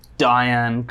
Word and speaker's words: Dayan: And this one really fell into place nicely from a cultural relevance standpoint, and also the Dayan kind of rhymes with Zohan, which is a film from the...